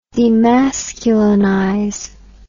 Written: demasculinization

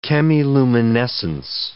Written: chemiluminescence

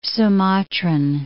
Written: Sumatran

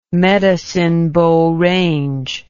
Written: Medicine Bow Range

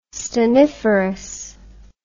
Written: stanniferous

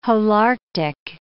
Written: Holarctic

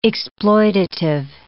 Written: exploitative

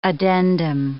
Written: addendum

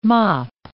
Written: mop

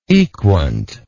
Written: equant

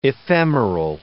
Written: ephemeral

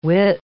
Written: width

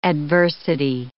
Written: adversity

